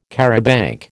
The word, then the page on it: charabanc: American English